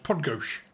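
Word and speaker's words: Podgórz: P-O-D-G-O-R-Z. It's pronounced Podgosh.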